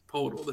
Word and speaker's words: Poeltl: Well, I wouldn't trade 2 first-round picks for Poeltl, but I wouldn't – or Poeltl either. It's Poeltl. There's no R in there.